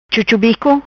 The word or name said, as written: Chuchubico